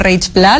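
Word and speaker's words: Reig Pla: Así es, bienvenido, Monseñor Juan Antonio Reich Pla, ¿lo estoy pronunciando bien? – Perfectamente.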